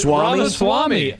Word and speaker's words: Ramaswamy: Vivek Ramaswamy is no longer a candidate for president. – Isn't it Ramaswamy? – Ramaswamy.